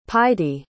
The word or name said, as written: páidí